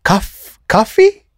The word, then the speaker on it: kawhe: How do you say CAFE in te reo? – Coffee? It's spelled K-A-W-H-E.